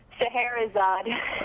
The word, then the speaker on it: Scheherazade: Author, writer, artist of Lucky, recent collection from Drawing Quarterly, as well as a plethora of anthologies, Mom Sharezada, how do you pronounce that? – Scheherazade.